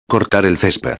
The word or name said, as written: cortar el cesped